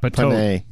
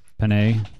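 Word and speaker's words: Petiot: Patois? It's not Patois. – Panay.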